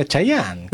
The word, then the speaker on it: Chayanne: Cheyenne, ¿cómo lo pronuncia? Chayanne. ¿Cómo Cheyenne? Chayanne.